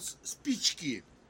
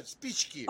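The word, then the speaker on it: spichki (спички): In Russian, matches called spichki. – Spichki? – Yeah, spichki. – Spichki. Did I pronounce that right? – Yes, right. Spichki. – Oh, good. – Like speech, spichki. – Spichki. – Spichki, yes.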